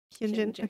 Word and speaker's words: Hyunjin: Hyunjin? – Yep. – Hyunjin.